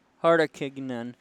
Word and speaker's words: Hartikainen: There it is. It is Hartikainen.